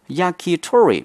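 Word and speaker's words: 焼き鳥: But in Japan, the two Chinese characters pronounced as yakitori means chicken skewer.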